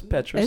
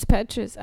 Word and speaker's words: Petras: I'm probably saying the name wrong. No, it's Petras. – It's Petras.